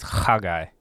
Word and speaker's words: Haggai: So I've always, since I learned Hebrew, I've always said it as Haggai, because you would probably put the emphasis on the last syllable.